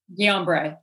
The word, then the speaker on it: Guillain-Barré: Guillain-Barré? – Guillain-Barré.